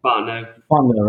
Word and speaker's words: Fontenot: How do you say his last name? I say Fontenot. Is that how you say it? – Fontenot, right? Fontenot. Okay, I've been saying it right.